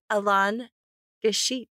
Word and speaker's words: Alain Guichet: Say hello to Alain Gachet. – Yeah, good pronunciation.